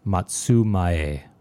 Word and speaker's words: Matsumae: Composer is Manami Matsume, or I don't know, maybe lately it's been brought to my attention that it may actually be pronounced Matsumae.